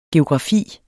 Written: geografi

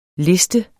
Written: liste

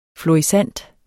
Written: FLORISSANT

